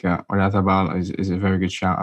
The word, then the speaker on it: Oyarzabal: My final pick as the attacker is Oyarzabal. Is that how you say it? – Oyarzabal.